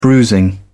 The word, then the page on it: bruising: British English